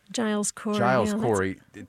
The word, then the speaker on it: Giles Corey: Is it Giles Corey? Am I saying his name right? – Giles Corey.